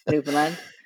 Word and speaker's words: Newfoundland: And great pronunciation, by the way, of that, Newfoundland.